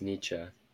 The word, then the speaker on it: Nietzsche: I want to say it's Nietzsche. They always add an or an eh, like at the end. – Oh, that last E is pronounced.